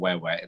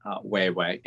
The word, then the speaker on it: Wewe: When they lost their mom, my dear sister, by the way, it's pronounced Wewe. It's Wewe, and it's spelled W-E-W-E.